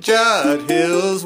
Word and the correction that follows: Judd's Hill: Now the first one, possessive, Judd's Hill. – Oh, Judd's Hill Winery.